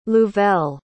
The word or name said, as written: louvel